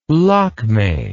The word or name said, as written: lakme